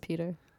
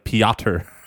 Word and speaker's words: Pyotr: I think it's just Peter. – It's not spelled like Peter. It's spelled like Pyotr. Pyotr.